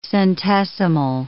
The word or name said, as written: centesimal